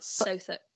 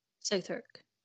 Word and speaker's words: Southwark: I think it is spelt that way, but you don't pronounce the W. So it's like Suffolk, but Southwark. Southwark. No W, okay?